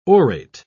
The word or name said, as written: orate